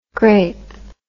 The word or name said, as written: graip